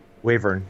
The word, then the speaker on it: wyvern: It's called a wavern. A wyvern? – Yeah. Wavern, yep. – Wavern. W-Y-V-E-R-N.